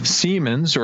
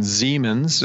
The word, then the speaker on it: Siemens: Let's start with the acquisition with Siemens, or if you prefer the German pronunciation, Siemens.